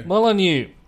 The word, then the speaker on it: Molyneux: Molyneux.